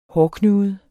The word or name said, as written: hårdknude